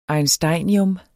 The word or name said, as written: einsteinium